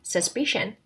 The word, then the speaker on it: suspicion: 再一次， Jealousy has its roots in envy, suspicion, mistrust, anxiety, and protective possession. 看第一个发音重点， its roots。好， TS 这个地方放在一起的话就会有点刺刺的感觉，所以是发 its roots， its roots。好，第二个发音重点， suspicion， suspicion， S-U-S 放在一起， U 发 e 的音，短的 e 的音， suspicion， P-I 发短的音，但是那个 P 发 b b b 的音， suspicion， C-I-O-N 跟 T-I-O-N 跟 S-S-I-O-N 一样都发省的音，所以再念一次 suspicion。